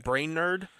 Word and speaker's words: Brainerd: It's spelled Brainerd. All right. It's like Brainerd, almost.